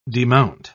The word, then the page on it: demount: British English